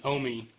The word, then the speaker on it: Yomi: And then Omi. I know on your sheet it's spelled Y-O-M-I, which it is spelled that way, but it's pronounced Omi.